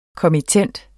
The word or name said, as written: kommittent